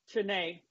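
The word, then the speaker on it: Chiney: And then you have, excuse me if I pronounce her name wrong, Penny Shinny Ogumike. – Chinay Ogumike, yep.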